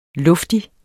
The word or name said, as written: luftigere